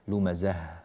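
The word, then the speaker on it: لمزة: في قوله تعالى لاحظوا أنه نهايات الآيات هنا عندنا تاء مربوطة أو هاء، لمزه وعدده وهكذا. هذه الهاءات لابد من العناية بنطقها بوضوح، هكذا لمزه، ولا نقول لمزه لمزه. بعض الناس يأتي بزاي مفتوحة وخلاص ما فيش بعدها أي شيء.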